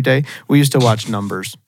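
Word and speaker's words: Numb3rs: He was in Numb-Threers. He was in that show. – What? – You ever never watched Numb-Threers? – Numb-Threers? – Yeah. I think it's pronounced Numbers, But it's spelled like num threers.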